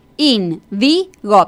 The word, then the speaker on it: índigo: Indigo, perfecto. Está perfecto. Ya, lleva tilde, además. – Sí, lleva tilde porque es esdrújula.